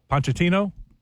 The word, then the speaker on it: Pochettino: He says, what the Americans head coach, how do you say his name? Pochettino? – That's it.